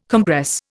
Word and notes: compress: Indian

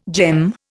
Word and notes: gem: Indian

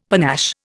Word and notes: panache: Indian

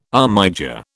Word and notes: armiger: British English

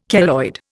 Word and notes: keloid: Indian

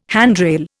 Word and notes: handrail: Indian

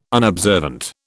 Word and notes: unobservant: British English

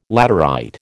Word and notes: laterite: American English

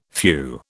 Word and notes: phew: British English

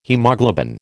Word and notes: haemoglobin: American English

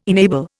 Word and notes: enable: Indian

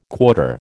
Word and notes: quarter: American English

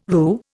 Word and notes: roux: Indian

